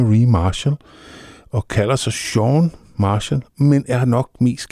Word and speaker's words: Chan: Ja, jeg har altid troet, det hed Sean Marshall, men har så fundet ud af, at jeg forberedte mig til det, at det faktisk udtales Sean.